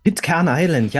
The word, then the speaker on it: Pitcairn Island: Von Pitcairn Island, wenn ich es richtig ausspreche. – Pitcairn Island, ja.